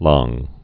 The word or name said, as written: langue